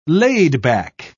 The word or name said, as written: laid-back